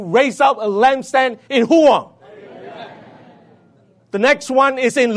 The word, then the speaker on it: Rouen: You may say Huang, but in French it's Huang. I'm being corrected. R-O-U-E-N. Huang.